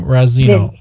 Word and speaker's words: Razzino: It's Vincent, last name Razzino. Razzino. Spelled like it's pronounced. Well, pronounced like it's spelled.